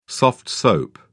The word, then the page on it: soft soap: British English